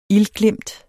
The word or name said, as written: ildglimt